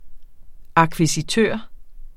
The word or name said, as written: akkvisitør